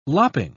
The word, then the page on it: lopping: British English